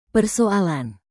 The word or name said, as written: persoalan